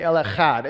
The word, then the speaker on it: El Echad: Um, but as I talk about El Echad, I'm going to say it like that because that's the way that it was intended to be said. And so, like I would say Yahweh, like that, I would say El Echad.